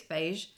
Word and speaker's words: Feis: I hope I've said that right, Irish people. Um, um, I've written in my notes, rhymes with Grage. Right. So I didn't get that wrong. So I think it's Faish. F E I S, um, and it's held at the Liverpool Pierhead.